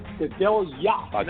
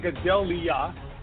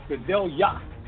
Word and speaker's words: Gadelha: Whenever you see the H and the A, it's pronounced like a Y-A. So it's Faja Gadelha. Not Gadelha. Gadelha. Faja Gadelha.